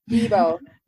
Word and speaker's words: Bebo: How do you say, is it Beeble or Bebo? – Bebo.